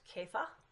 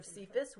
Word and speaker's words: Cephas: I've heard it pronounced Kepha instead of Cephas. We always say Cephas, but I think that's an Englishized.